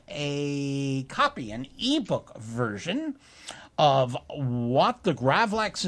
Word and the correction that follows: Grawlix: is that. I said it wrong, didn't I? You know what I think? It's a Grawlix.